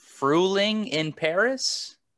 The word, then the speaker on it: Frühling in Paris: It is Frühling in Paris. – Ooh. – Is that how you say it? – Yes, pretty much.